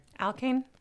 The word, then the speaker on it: alkane: So it's the process of deconstructing large alkyne. How do you say that word? – Alkane. – Alkane. Thank you.